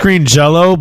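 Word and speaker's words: Green Jellÿ: Jelly now, or is it green Jello? Are they able? – It was it's green, pronounced green Jello, but it's spelled green jelly.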